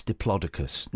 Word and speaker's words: Diplodocus: And then about 4 or 5 years ago, it was pointed out to me, it's Diplodocus.